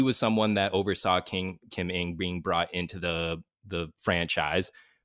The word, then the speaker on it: Ng: But we have Kim Ng in the list. I want people to, it's spelled NG. You're going to be seeing something unfamiliar there, unorthodox there.